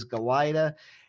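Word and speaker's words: Galajda: who brings back sophomore goalie Matthew Galita, who, apologies, we had to spend most of last season figuring out how to pronounce his name, but it is Galita, and he is maybe the best goalie in the East, maybe the best goalie in the country.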